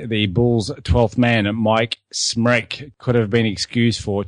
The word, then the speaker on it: Smrek: Stan Albeck wanted to keep Dave Corzine in his regular role off the bench, leaving the Bulls' 12th man, Smrek, pronounced wreck as in nervous wreck, wrote the sack.